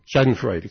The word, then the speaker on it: Schadenfreude: It's the age of Schadenfreude, which I probably have mispronounced. – Schadenfreude, excuse me.